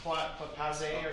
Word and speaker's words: passé: Religion is, what's that when they say it's past, passé or something? – Passé.